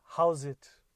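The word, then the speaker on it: Howzit: So that greeting is called how's it. I'm going to say that again. How's it? It's spelled H O W Z I T O Z A T.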